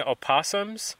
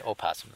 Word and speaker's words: opossum: Eggs and nestlings are taken by black rattlesnakes, Virginia opossums, raccoons, and ringtails. – Only weird people say opossum, though. – That's what I read in the literature. Someone used opossum. – So, it's a silent O.